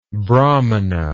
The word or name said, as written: brahmana